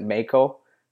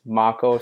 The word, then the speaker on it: Mako: And I have to make sure that I say that properly all the time too, because I would always pronounce it Mako with a Canadian accent, but it's Mako. So I kind of have to remember it like I'm saying Marco Polo, but without the R.